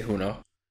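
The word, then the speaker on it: Junot: I would say Juno.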